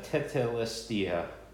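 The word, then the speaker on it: tetelestai: Picture it as, if you will, that word "finished" in the Greek is pronounced tetelestia, which means mission accomplished.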